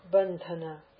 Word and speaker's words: bandhana: If I say bandhana, the accent is on the dha, bandhana, just because of the aspiration.